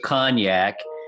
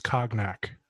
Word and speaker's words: cognac: So, Cavassier is a brand of cognac. I was making a callback. – I'm sorry. I think you pronounced that incorrectly. It's cognac. Okay. It's cognac.